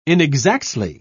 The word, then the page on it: inexactly: British English